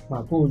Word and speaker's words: Makú: Em, el proyecto Macu, Macu, Macu, Macu lleva acento en la U.